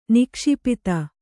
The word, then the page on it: ನಿಕ್ಷಿಪಿತ: nikṣipita